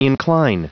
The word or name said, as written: incline